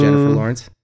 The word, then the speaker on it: Jennifer Lawrence: I believe it is Jennifer Lawrence.